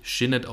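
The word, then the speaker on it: Sinéad: mein erster Song ist Shinnet O'Connor und ja, die wird wirklich Shinnet ausgesprochen. Ich habe es extra nachgeguckt.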